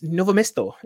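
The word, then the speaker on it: Novo mesto: It was TEDx Novomesto. Is that right? Did I say that right? – Yeah.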